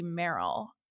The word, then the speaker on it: Merrill: It used to be Merrill, but nobody knows how to spell it or say it. They'll go Morel, Marl. I'm like, there's no A in there.